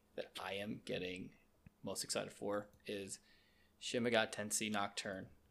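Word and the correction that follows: Shin Megami Tensei: Yeah, but yeah, Shin Megami Tensei.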